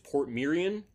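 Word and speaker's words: Portmeirion: It is a town called, and I'm hoping I'm pronouncing this right, I think I heard this on the documentary, it's Port Mirren in Wales.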